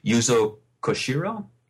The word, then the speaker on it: Yuzo Koshiro: Yeah, the soundtrack by, and I hope I don't say his name wrong, Yuzo Koshiro? – That's correct.